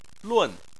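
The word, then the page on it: lun: tone 4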